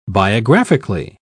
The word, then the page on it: biographically: British English